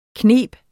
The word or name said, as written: knibe